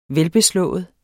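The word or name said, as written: VELBESLÅET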